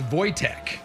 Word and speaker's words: Wojtek: This was in World War II, and his name was, it's actually pronounced Wojtek,